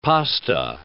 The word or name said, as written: pasta